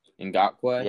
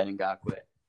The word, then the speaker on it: Ngakoue: How do you pronounce, his name? The Ravens guy. – Ngakoue. – Yeah, Ngakoue. Yeah, he's terrific.